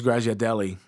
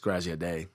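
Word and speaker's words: Graziadei: For years, I thought it was Grazia Deli, and then I heard him on the Josta show, and it's Grazia Day.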